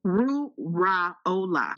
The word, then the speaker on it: Wuraola: Wuraola.